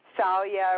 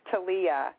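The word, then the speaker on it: Thalia: a girl, I work at a high school, and there's also it's T-H-A-L-I-A. I was saying Talia or Salia or whatever. Actually, one of her teachers corrected it for me. She said it's Talia. It's pronounced, Talia. – The H is silent, I guess.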